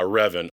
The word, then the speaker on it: Revan: Yeah, Revan.